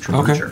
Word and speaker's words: trebuchet: A netting ammo for the trebuchet. Just a sketch to start to design a very simple bolo for the trebuchet. – Pretty sure that's pronounced trebucher. Okay.